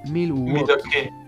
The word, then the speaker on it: Milwaukee: Y en realidad se pronuncia Milwaukee. Milwaukee.